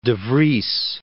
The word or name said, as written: Vries, de